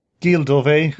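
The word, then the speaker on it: Gilles Deleuze: like Gilles Dave, who I'm probably mispronouncing his name. Your European listeners can cringe every time I say anything French. – Gilles Dove. – Yeah, Gilles Dove.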